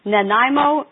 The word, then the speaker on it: Nanaimo: Nanaimo, British Columbia. It's like the Nanaimo Bar. If you know desserts, Nanaimo Bar is a delicious dessert. If you can remember that, that's how you pronounce it.